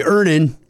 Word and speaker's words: Earnin: I'm going to pronounce this properly. Earnin. – Earnin. – E-A-R-N-I-N is pronounced earnin.